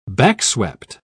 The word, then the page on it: backswept: British English